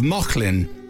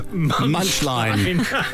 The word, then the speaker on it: Mauchline: But it's spelt M-A-U-C-H-L-I-N-E, which I, in my ignorance, had read as Munchline.